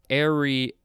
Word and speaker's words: ariary: First word for this episode is ariary. I really like saying that. Ariary. I think the emphasis is on the third syllable. Ariary. It is spelled A-R-I-A-R-Y.